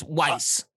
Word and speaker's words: Weiss: As far as episode five, I'm really I really love the introduction of Weiss or Wise, however you say his name. And um Yes, Weiss like like you said, he's one of my favorite.